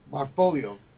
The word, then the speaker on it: Marfolio: Marfolio.